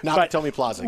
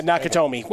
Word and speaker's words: Nakatomi: I didn't want to mispronounce it because I saw. No, it's okay. Nakatomi Plaza. – Yes. Yeah, Nakatomi. Well, I wanted to add an extra syllable.